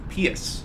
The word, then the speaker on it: Pius: Now, is it really Pius, though? – I believe it's pronounced Pius.